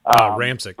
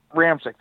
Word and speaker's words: Ramczyk: Ramczyk. – But he's Ramczyk, thank you, yep.